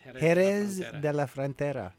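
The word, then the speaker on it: Jerez de la Frontera: Okay, so your training started at McGill University and followed by Sevilla, Spain, and Juarez? How do you say that? – Jerez de la Frontera.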